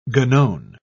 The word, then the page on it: guenon: British English